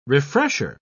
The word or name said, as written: refresher